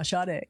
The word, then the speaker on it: Sade: Yeah, I said it wrong on live on radio. I called him Sade. I'm like, oh, I never lived that one down. So yeah, Sade.